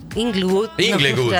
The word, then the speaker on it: Inglewood: Y también después en Estados Unidos, en el decime si lo pronuncio bien, en el Sophie Stadium de Inglewood. – Inglewood. – Está medio raro el nombre.